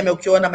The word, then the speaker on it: Melchionna: Porque pronuncia Melchiona, mas escreve com CHI dois N's.